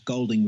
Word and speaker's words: Golding: CK, as in Calvin Klein, would be my first name, and surname is spelt GOLDII, N for November, G for golf, Golding with two I's.